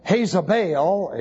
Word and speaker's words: Jezebel: That would be I-E-Z-A-B-E-L. And as you can see immediately, that's our English word, Isabel. In the Hebrew, it's Jezebel is the way it's pronounced, but it wasn't really. It was Hezabel in Hebrew, Iezabel in Greek, and Isabel.